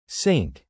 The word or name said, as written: sync